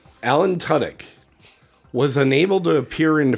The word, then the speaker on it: Tudyk: Alan Tudyk, which is the correct way to pronounce the name. – Tudyk, yep.